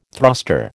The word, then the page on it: thruster: American English